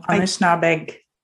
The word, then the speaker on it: Anishinaabeg: the territory of the Algonquin Anishinaab people. – Anishinaabeg. – I just want to say that I definitely mispronounce this word. Algonquin Anishinaabeg people, which means that these people were there way before anybody came to the country and especially Europeans, I guess.